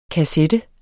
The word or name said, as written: kassette